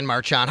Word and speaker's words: Marchand: Marchand. How do you say it? How do you say it? – Marchand. – You say Marchand? Marchand.